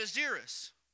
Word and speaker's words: Ahasuerus: at Ahasuerus. Did I say that right, Brother Buford? Okay.